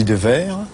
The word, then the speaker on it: Dewaere: Alors moi, je dis Devers.